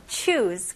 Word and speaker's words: choose: 여기서는 스펠링이 C-H-O-O-S-E 이지만 발음은 Z 발음이 나와야 됩니다.